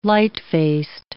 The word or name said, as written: lightfaced